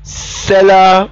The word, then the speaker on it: sclera: Cellera is spelled S-C-L-E-R-A, choroid, C-H-O-R-O-I-D, and retina.